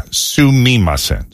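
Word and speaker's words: Sumimasen (すみません): So the Japanese word for excuse me is sumamisen. – Sumamisen. – Sumamisen. – Okay. – Yeah. I'm sorry. I added a syllable to this. Yeah. Sumimasen. Sumimasen. I said it right.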